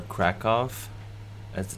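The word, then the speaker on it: Kraków: And then I went to Krakow, Poland, or Krakow, as it's pronounced. – Do they say Krakow? – Yeah. – Oh, because it's a W?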